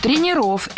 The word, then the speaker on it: тренеров: Тренеров – это неверно. Слово «тренер» имеет неподвижное ударение на основе, поэтому сохраняем это ударение на первом слоге во всех формах. Тренеров, тренером, тренерами.